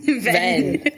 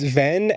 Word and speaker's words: Venn: Venn. – It's Venn as in Ventus, you know, like the Roxas copy in Kingdom Hearts Birth by Sleep, obviously.